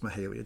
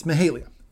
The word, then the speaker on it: Mahalia: I mispronounced her name. It's Mahalia Jackson, not Mahalia, but anyway. – I did so earlier, too. – I do that all the time. It's Mahalia.